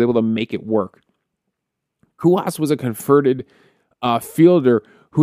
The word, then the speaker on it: Cuas: And when Jose Cuas, I believe that's how it's pronounced, Cuas, when he made his debut, this was a big story for the Royals.